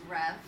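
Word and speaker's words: Rêve: So I believe it would be pronounced Rev, which is French for dream.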